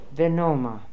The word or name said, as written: Venoma